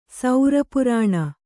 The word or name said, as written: ಸೌರಪುರಾಣ